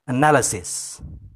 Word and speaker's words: analysis: Ya, yang benar adalah analysis. Ya, jadi itu dibaca analysis. Banyak dari rekan-rekan saya atau murid-murid saya yang sering mengucapkannya dengan salah, yaitu mengucapkannya dengan analysis.